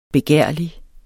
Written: begærlig

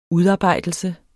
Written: udarbejdelse

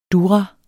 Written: durra

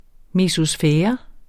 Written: mesosfære

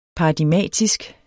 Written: paradigmatisk

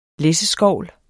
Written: læsseskovl

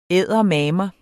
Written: eddermame

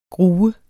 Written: gru

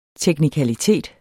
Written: teknikalitet

